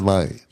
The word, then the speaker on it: Lumet: Directed by Sidney Lumet. I hope I'm pronouncing that correctly. – Sidney Lumet.